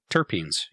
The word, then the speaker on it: terpenes: Am I saying this right? Terpenes? – Terpenes, yep.